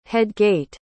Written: headgate

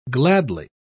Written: gladly

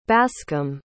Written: bascom

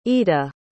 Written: eda

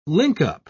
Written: linkup

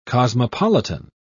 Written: cosmopolitan

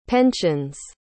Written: pensions

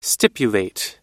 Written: stipulate

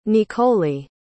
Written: niccoli

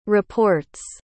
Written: reports

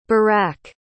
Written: barrak

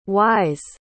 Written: whys

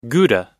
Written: gouda

